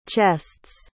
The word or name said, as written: chests